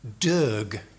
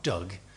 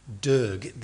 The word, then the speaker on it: Doeg: Don't know if that's how you pronounce it in Hebrew, but Doeg the Edomite, not Doug, Doeg the Edomite.